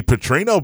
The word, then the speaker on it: Petrino: They brought Bobby Petrino back. It rhymes with Pitino.